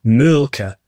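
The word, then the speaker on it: Mölkky: Most of the people we play against use Mulkey, but the Finns have a very unusual pronunciation, which, please forgive me, I'll try it. It's Mulka. So I think that's, something a bit more like that. But to us, it's Mulkey.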